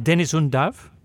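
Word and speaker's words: Deniz Undav: How should it be pronounced? Dennis Undav? – Yes, that's right. That's perfect pronunciation.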